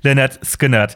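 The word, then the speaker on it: Lynyrd Skynyrd: Kein Schwein wusste, wie sie ausgesprochen werden. Ähm, dann haben sie aber das Album äh, so genannt, wie sie ausgesprochen werden, nämlich äh, Le-Nerd Skin-Nerd.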